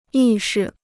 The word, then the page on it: 意识: yì shí